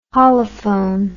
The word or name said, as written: holophone